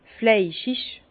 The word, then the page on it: fleischig: Bas-Rhin; Herrlisheim